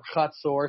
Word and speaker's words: Hazor: And then in the north, we have the destruction of either Hazor, if you want to, you know, pronounce it like a English speaker, or Hazor, if you want to be fancy schmancy, you know, in the north, the head of all those kingdoms.